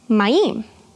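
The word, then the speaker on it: mayim (מים): Waters is pronounced mayim.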